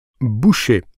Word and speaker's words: Boucher: Boucher. Ça s'écrit B-O-U-C-H-E-R.